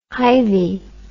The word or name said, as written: hivey